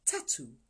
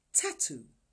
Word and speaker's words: tatu: Three is pronounced tatu tatu.